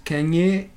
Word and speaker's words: Kanye: Su nombre completo es Kanye Omari West. La pronunciación correcta según es. – Kanye.